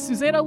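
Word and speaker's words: Susannah: So my name is Susanna Lewis, otherwise known as Keeper Susanna, which is what you can find me under on Twitter.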